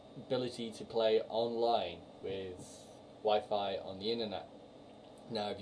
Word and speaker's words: Wi-Fi: News on Wirefire. I said it right for once. On Nintendo. – Wi-Fi. – Said it wrong.